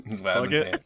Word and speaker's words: Fugit: Fugit.